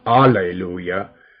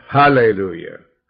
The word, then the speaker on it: Hallelujah: Not Alleluia, but Hallelujah. Let's say it right. The H, it stands for God.